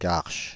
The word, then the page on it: Garches: French; [ɡaʁʃ]